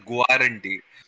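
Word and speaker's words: guarantee: ज, जैसे गारंटी का spelling it is written as गारंटी पर गारंटी बोलते हैं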